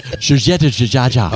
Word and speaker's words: Giorgetto Giugiaro: Giorgetto Giugiaro.